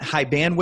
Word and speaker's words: Hiban: All right, today on the show, we have Pat Hiban with us. Hiban? I don't know what you prefer, Pat. – Hiban. My grandfather used to sing, Hiban working on the railroad.